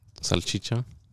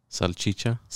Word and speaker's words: Salchicha: Salchicha. – Oh, okay. Because I know huevos is the eggs. – Is the eggs, but I couldn't say think it. Of the what. – Salchicha.